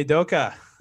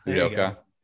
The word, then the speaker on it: Udoka: Is it Ime Udoka? – Ime Doka. – Udoka.